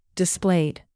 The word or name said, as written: displayed